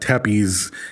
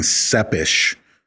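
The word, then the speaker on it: Țepeș: And for what it's worth, that same student said, because we kept saying Vlad Tepes, and when she pronounced it, she actually said, and I know I can't say it the way she said it, but it was closer to saying Sepish.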